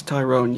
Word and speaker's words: Tyrone: Yes, it's actually pronounced Tyrone, but I'm just used to being called Tyron. Yeah, it's Tyrone, yeah.